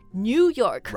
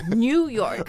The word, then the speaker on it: New York: It's new, New York. – Right, but now we say New York. – Ah, New York. – Right. – New York、mm-hmm. 所以就是你发这个城市这里面带 new 的发音的时候就不要把它发成 new 而是 new、 yeah.